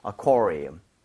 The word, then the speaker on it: aquarium: UA 쪽에다가 강세를 좀 많이 주시면서 Aquarium. 이렇게 가주시면 되겠습니다.